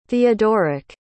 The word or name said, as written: theodoric